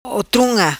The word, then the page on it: Otrunga: patua